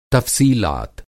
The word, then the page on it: तफ़सीलात: تفصیلات